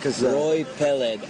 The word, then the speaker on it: Roy Peled: Roy Peled, R-O-Y-P-E-L-E-D.